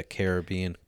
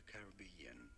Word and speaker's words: Caribbean: How to pronounce Pirates of the Caribbean correctly. – It's pronounced once too, including movie names, so make sure to stay tuned. – What? – How do you say it? Pirates of the Caribbean.